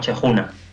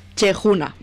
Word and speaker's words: Cheikouna: ¿Cheikouna se pronuncia tal cual? – A nosotros nos costó mucho tiempo aprender a pronunciarlo y probablemente los doctores en la materia nos digan que lo pronunciamos mal. Pero bueno, aquí al castellano se pronunciaría algo así como Chejuna.